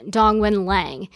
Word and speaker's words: Dongwen Lang: So my legal name is Dongwen Lang. It sounds completely different in Chinese.